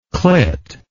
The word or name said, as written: clit